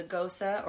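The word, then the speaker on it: Gose: It's a Gosa or Goes, depending on how you pronounce it. I've started saying Gosa, so... let us know if I'm wrong.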